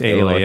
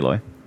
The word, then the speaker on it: Aloy: Like, is it Aloy? – It's Aloy. – Yeah, they pronounce it Aloy.